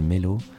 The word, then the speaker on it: Meylo: Et, euh, et puis très vite, j'ai rencontré une chanteuse qui s'appelle Melo, euh, s'écrit M-E-Y-L-O, et j'ai adoré sa voix.